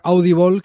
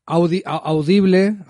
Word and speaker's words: Audible: Yo lo estoy viendo en audiolibro, porque está Audible, que lo dije mal la otra vez. Audible es un sistema de videolibros, una nueva plataforma que está desarrollando Amazon.